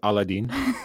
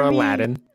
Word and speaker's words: Aladdin: Or Aladdin, as the American English pronounces it.